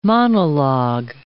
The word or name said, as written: monolog